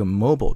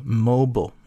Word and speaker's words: mobile: 注意一下这个 mobile 这个词、美音的发音呢其实读作 mobile、就后面 B-I-L-E 读 bo 这个音，然后英音其实是读作 mobile。